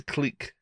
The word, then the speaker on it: clique: It's a clique. – It's a clique? Is that really how you say it? – It's your French.